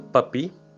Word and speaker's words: Papus: mas se pronuncia o apelido Papi e se escreve Papus, Papi porque é, inglês, é francês.